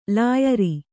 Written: ಲಾಯರು

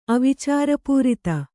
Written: ಅವಿಚಾರಪೂರಿತ

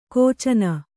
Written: ಖಚನ

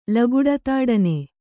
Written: ಲಗುಡತಾಡನೆ

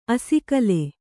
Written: ಅಸಿಗಲ್ಲು

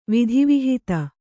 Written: ವಿಧಿವಿಹಿತ

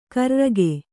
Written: ಕಾರೆರಕೆ

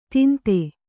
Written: ಧಂಧೆ

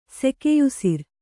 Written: ಸೆಕೆಯುಸಿರ್

